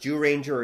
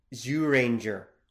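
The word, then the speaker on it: Zyuranger: It can be, I should point out, it can be pronounced Jew Ranger, or it should technically be Zoo Ranger. Like, there's a weird sound there that we're kind of skipping around.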